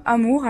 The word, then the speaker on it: A.M.O.U.R: Et euh, donc cet album, Amour, alors ça se prononce A-M-O-U-R, en fait il y a un point entre chaque lettre, parce qu'il le dit, euh, l'amour c'est un sujet euh, compliqué, en tout cas parfois ponctué.